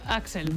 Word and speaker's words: Axl: Estoy hablando de Axl Rose. ¿Lo he dicho bien? – Sí, Axl.